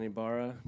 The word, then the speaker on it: Ibarra: It's actually Ibarra. Like, you're supposed to, I don't even say it right, but you're supposed to really roll your R's and like, Ibarra.